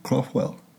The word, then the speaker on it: Craughwell: I'm in Galway City Council buildings here with Jason Craughwell.